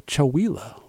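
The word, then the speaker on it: Chewelah: I recently got noticed that it's pronounced like tequila, so Chowila, Washington.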